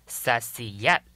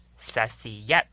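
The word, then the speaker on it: Saisiyat: 所谓的塞夏族，他们的族语发音叫做 Sasiyat Sasiyat，而且呢，汉文呢又叫做萨斯特施舍族，因为跟他们的族语发音很相似。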